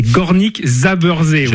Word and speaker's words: Górnik Zabrze: C'est Górnik Zabrze, voilà.